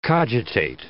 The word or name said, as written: cogitate